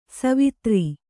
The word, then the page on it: ಸವಿದೋರು: savidōru